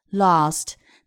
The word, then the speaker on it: last: 那么大家注意，last 在这个地方，如果是英式发音读的就是 lost。那么如果是美式呢，a 在其中发四号元音 a 梅花音 a last last few hot dogs。